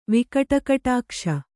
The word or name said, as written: ವಿಕಟಕಟಾಕ್ಷ